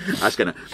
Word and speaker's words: Azkena: Goyas lo dice bien, porque yo dije Azkena y no, es Azkena. No se dice Azkena, se dice Azkena.